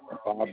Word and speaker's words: Bob: Mills, Bob Mills. – You say your zip code was 42376? – Yep. – Okay, and what was your name? – Bob Mills. – Spell that for me. – B-O-B-M-I-L-L-S.